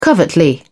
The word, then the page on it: covertly: British English